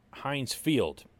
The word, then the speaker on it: Heinz Field: I'll be covering Steelers minicamp this week at Heinz Field.